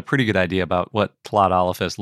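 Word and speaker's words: Tlatolophus: I think the coolest hadrosaur we talked about this year was in episode 340, and that was Tlodolophus. – Oh, yeah. Good job remembering how to pronounce that. – Yeah, the lateral fricative, just like Welsh.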